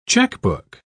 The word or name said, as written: checkbook